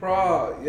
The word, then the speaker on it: Pras: But before we get to that, the Fugees, Praz, did I say his name right? – Praz, yeah.